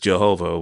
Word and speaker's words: Jehovah: Y-A-H-W-E-H. And then later, vowels were added to that name. So now it's pronounced Jehovah.